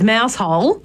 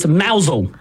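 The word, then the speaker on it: Mousehole: Oh, it's actually mousel. – Mousel. – But it's spelt mouse hole. – It is spelt like mouse hole.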